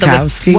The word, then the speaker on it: Wachowski: Wachowski.